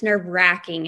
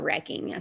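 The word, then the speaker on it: nerve-wracking: I learned it's nerve wracking and not wrecking. So I've been saying that wrong my whole life.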